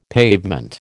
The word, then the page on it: pavement: American English